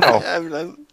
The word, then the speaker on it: Fire Emblem: Ja, Fire Emblem.